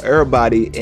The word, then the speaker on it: Errbody: So getting into Lil Baby's singles for his newest album, he dropped a track called, okay, I was about to say Everybody, but it's spelled like Errbody, so he dropped a track called Errbody and On Me.